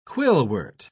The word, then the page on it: quillwort: British English